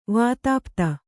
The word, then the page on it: ವಾತಾಪ್ತ: vātāpta